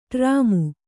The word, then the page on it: ಡ್ರಾಮು: ḍrāmu